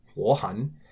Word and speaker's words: 我很: So 我很, meaning I'm very, is a double third tone, but pronounced second tone, third tone because of the tone exception rule number one, 我很.